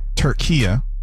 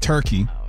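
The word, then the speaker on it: Türkiye: I've been told it's now pronounced Turkia. It's not Turkey.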